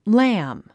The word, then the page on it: lamb: lǽm; ラム